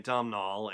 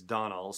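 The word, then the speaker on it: Domhnall: All right, so Donald Gleason, and I've been saying it Domnall, and apparently it's Donald,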